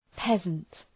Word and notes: peasant: ‘pezənt

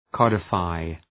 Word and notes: codify: ‘kɒdə,faı

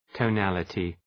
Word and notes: tonality: təʋ’nælətı

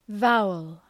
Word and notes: vowel: ‘vaʋəl

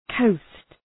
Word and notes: coast: kəʋst

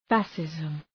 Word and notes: fascism: ‘fæʃızm

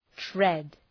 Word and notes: tread: tred

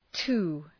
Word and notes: to: tu:, tʋ, tə

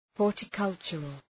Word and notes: horticultural: ,hɔ:rtə’kʌltʃərəl

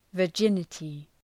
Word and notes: virginity: vər’dʒınətı

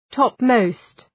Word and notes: topmost: ‘tɒp,məʋst

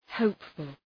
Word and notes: hopeful: ‘həʋpfəl

